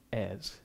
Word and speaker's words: heirs: So those headings are humanity, humility, and a silent H, heirs.